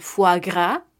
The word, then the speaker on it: foie: Cuando encontremos una O y una I juntas, esta se pronunciará como una U, A. Entonces, algunos ejemplos serán le foie gras, este alimento, le foie gras, artículo le, masculino singular, el e, foie, F-O-I-E, que es hígado, F-O-I-E, y gras, G-R-A-S, gras, de graso, hígado graso, estaremos diciendo le foie gras.